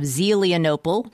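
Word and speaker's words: Zelienople: Angela Autumn is from just outside Pittsburgh, PA, in the Appalachian town of Zelianople, which, yes, I am patting myself on the back for pronouncing it correctly to all my Zeely fans.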